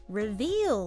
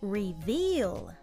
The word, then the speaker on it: reveal: 今日はこんな感じで reveal 言ってみましょう。 – Repeat. Reveal. Reveal. – R の音のすぐ後で V が入るので、ちょっと発音に要注意ですね。